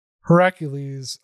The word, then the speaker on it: Heracles: One of them is that Hercules, or I should pronounce it properly, Heracles, made pillars to hold up the sky.